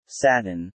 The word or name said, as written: satin